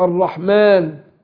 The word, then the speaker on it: الرحمن: لكن في الرسم أصل رسم المصحف مفيش ألف، لكن إحنا بننطقها بالنطق ننطقها ألف، الرحمن في ألف أهو بننطقها إيه ألف، لكن هي في الرسم مش موجودة، ولذلك يقول لك البسملة 19 حرفا رسما و20 حرفا نطقا، اللي هو الألف الإيه؟